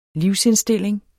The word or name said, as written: livsindstilling